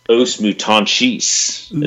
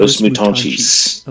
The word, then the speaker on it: Os Mutantes: and this band's actually called Os Mutantes, is how you say it in Brazil. Os Mutantes. – Okay. – Like, with a CH towards the end.